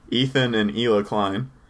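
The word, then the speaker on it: Ethan and Hila Klein: They just have a huge following, which is the husband and wife team of Etha and Elon Klein. I just totally pronounced their name wrong. Ethan and Ela Klein.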